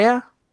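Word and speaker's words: Weah: So I think Tim Wee? Is that how you pronounce it? Or Wea? – Wea. – Okay. No, not Way.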